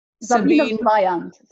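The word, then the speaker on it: Sabine Weyand: I can never say that right. I'm so sorry, Sabine. Can you say it? Say it. – Sabine Vaillant. – Okay. – You are not Vaillant. Doing so badly. – Okay. Sabine Vaillant.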